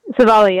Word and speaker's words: Civale: Aaron Civale. Am I saying that right? – Savali, yep.